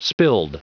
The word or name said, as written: spilled